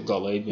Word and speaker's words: Gottlieb: But back to the article, Gottlieb said, I hope I'm saying that right, Gottlieb. Does it look like Gottlieb? – Yeah, good little Gottlieb.